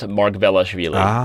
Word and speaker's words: Margvelashvili: Think of Machiavelli. It's Margvelashvili.